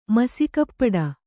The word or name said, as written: ಮಸಿಗಪ್ಪಡ